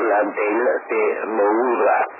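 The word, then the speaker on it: Landell de Moura: Mal der Geburtstag des Erfinders und Funkpioniers Pater Roberto Landel de Moura. Jetzt habe ich es richtig ausgesprochen.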